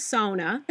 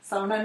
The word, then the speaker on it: sauna: So as well as many musicians at this time, you've been doing some fun live stuff, sometimes from sauna. – Yes. Mm-hmm. Sauna, not sauna. – It took me years to learn how to say it properly, and now I make a point of saying it properly.